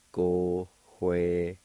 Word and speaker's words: Gohwele: In the fourth part, there's an H sound right before the W. It's pronounced like this. Gohwele.